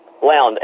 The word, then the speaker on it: Lound: Lound, L.